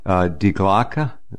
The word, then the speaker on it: Die Glocke: By the way, I'm working on revising the picture story about Die Glocke, that's how you pronounce it, the bell in German,